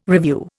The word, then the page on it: revue: Indian